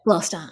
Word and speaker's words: Gloucester: Is it Gloucester? – Yeah, Gloucester. Yep. – Gloucester.